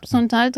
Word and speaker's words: Santal: Und zwar Santal 33. Spricht man das eigentlich so aus? – Santal, ja, Santal 33 ist ein Duft der New Yorker Parfümerie und Kosmetikmarke Le Labo.